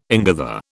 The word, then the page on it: ingather: British English